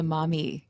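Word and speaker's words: Maumee: Is it Mame? – The Mami. Yes. – Mami, as in Mami. – Kind of, yes.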